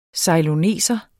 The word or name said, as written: ceyloneser